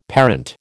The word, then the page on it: parent: American English